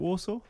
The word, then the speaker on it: Warsaw: Is it Warsaw? Is that how you say it? – Yeah, Warsaw.